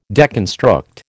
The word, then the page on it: deconstruct: American English